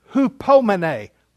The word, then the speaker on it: hupomene: It is the Greek word hupomene. Let me spell it for you. H-U-P-O-M-E-N-E. Hupomene.